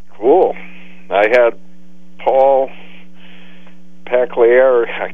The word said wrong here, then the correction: Pasqualoni: Paul Pasqualoni.